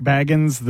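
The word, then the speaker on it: Bagans: It's unclear how to say his name. He says Baggins. Everybody else reads it as Baggins. I think to make him sound a little more ridiculous.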